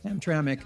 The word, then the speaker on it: Hamtramck: Hamtramck? Is that how you pronounce it? – Hamtramck.